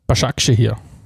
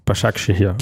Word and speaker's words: Başakşehir: Bascha Keshia. Başakşehir. Başakşehir.